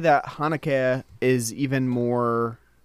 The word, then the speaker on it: Haneke: I always want to say Haneke, and that's probably the American in me, the you know, northeastern American in me that wants to say it like that. But Haneke, you might as well just say it like that.